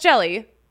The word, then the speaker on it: Hjelle: It's spelled H-J-E-L-L-E. It's pronounced jelly.